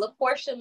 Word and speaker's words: LaPortia: LaPortia Mitchell Presents.